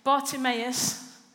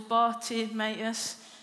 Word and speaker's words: Bartimaeus: Because Bartimaeus, I think it's four syllables, Bartimaeus, and it's really going to slow us down this morning.